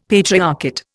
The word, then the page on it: patriarchate: Indian